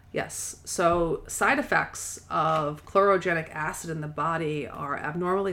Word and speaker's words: chlorogenic acid: Let's see, I think it's spelled chlorogenic acid.